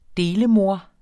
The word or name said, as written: delemor